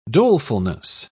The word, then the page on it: dolefulness: British English